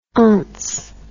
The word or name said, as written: ence